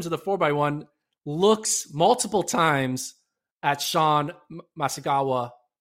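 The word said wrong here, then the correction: Maswanganyi: Am I saying his name right? I just want to make sure I'm saying his name right. – Maswangani. – Excuse me. Apologies, Sean.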